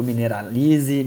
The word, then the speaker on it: Mineralize: Ah, eu que agradeço aí a, oportunidade de falar aí no MineraCast, pro Mineralize, Mineralize, como é que fala isso aí? A gente chama de mineralize, cara.